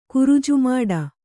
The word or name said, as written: ಕುರುಜುಮಾಡ